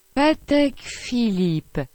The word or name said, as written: Patek Philippe